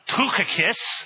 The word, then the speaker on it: Tychicus: In Greek, it would be something like Tuchikis.